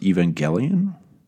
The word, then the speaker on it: Evangelion: Not too terribly versed on the anime TV shows, but I believe it is pronounced Evangelion. – Evangelion.